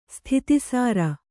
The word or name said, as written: ಸ್ಥಿತಿಸಾರ